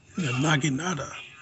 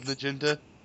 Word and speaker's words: Naginata: Naginata. – I still remember that episode of League where Taco kept mispronouncing the word. He calls it his Naginta. Naginta.